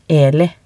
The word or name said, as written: Eli